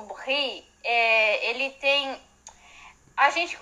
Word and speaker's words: brie: É, a pronúncia correta do, do queijo é brie.